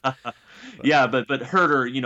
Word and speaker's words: Huerter: I actually did a podcast earlier today with someone who was calling him, I believe it was Huerter. It's Kevin Huerter, which it's another weird spelling versus pronunciation. So if you don't do all, trust me, I think I was saying it wrong for the first like 2 weeks, and people kept correcting me, but it is Kevin Huerter.